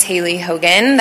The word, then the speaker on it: Hailey Hogan: For the record, my name is Haley Hogan. That is spelled H-A-I-L-E-Y-H-O-G-A-N.